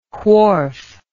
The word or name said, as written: Whorf